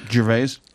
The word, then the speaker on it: Gervais: Gervais.